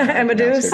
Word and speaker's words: Amadeus: Look, I think we can conquer the hospitality space and be as big as, you know, how do you pronounce Amadeus? – Amadeus. Yeah. – Sorry. My French comes out sometimes and I don't know. Amadeus. That's right.